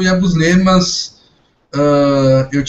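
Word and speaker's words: Yabusele: O, Ia, o Yabusle, como o, o Daniel falou no, no, no último Pod Celtics, que era a pronúncia correta, né? Yabusle.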